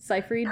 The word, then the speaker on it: Seyfried: We think Megan Fox and Amanda Seyfried. Did I say her name? I've never said it. – Seyfried.